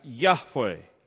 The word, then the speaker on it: Yahweh: Now, we believe that name is pronounced Yahweh. We don't know,